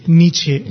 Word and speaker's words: Nietzsche: started speaking about the German philosopher scholar Nish. I hope I'm pronouncing that name right. – Nietzsche.